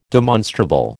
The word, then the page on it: demonstrable: American English